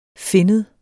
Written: finnet

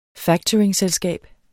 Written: factoringselskab

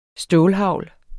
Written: stålhagl

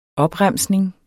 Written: OPREMSNINGERNE

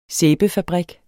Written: sæbefabrik

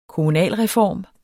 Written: kommunalreform

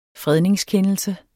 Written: fredningskendelse

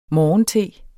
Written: morgente

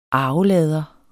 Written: ARVELADER

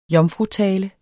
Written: JOMFRUTALE

